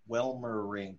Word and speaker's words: Welmerink: Yeah, we have Peter Welmerink. – Yeah, I did it. I didn't butcher the name. – Yep. All right. If you do it in three syllables, it's perfect.